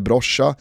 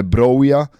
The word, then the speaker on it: Broja: det är inte Broja eller Broscha eller Broja, utan det är Armando Broja.